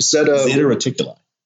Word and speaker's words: Zeta Reticuli: Big ziti. How do you say it? – Zeta Reticuli. – That sounds way better than what I said. – Well, because that's the real pronunciation.